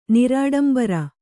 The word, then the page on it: ನಿರಾಡಂಬರ: kannada